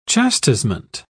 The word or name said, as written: chastisement